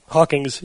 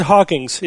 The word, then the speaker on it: Hawking: Hawking's, yeah. – Did I say it wrong? – No, she did. She said Hawking's. It's Stephen Hawking, but she kept calling it. I said Hawking.